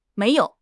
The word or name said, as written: mei2 you3